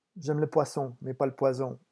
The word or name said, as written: j'aime